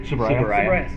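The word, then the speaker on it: Tsuburaya: It's kind of interesting too, part of his, was it Sabira's, am I saying his name right? – Subaraya. – Thank you.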